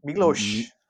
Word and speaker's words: Miloš: Miloš.